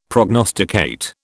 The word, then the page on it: prognosticate: British English